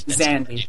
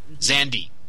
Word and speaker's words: Zandy: Zandy. – Zandy. Mm-hmm. All I have to do is think of a certain Bruce Springsteen song and mispronounce the first letter.